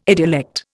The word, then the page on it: idiolect: Indian